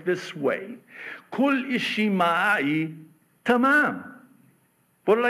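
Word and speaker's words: tamam (تمام): It's the same word in my native tongue of Arabic pronounced tamam.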